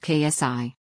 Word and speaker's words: Xi: The dictator's name is pronounced Qi while the Greek letter Xi is pronounced KSI.